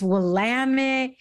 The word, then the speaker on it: Willamette: Now, I will say, I think I had told you my sister lives in the Portland area and I kept calling it Willamette Valley. And she's like, no, don't say that. They will correct you. It's Willamette.